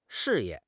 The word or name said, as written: 事业